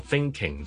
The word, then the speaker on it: thinking: 譬如我 thinking 就好多人會讀成 thinking，thinking，what are you thinking about？What are you thinking about？Thinking about， thinking，正常系应该 thinking about 用 ng 嘅，但系懒咗咧就会变咗 n 音 thinking about。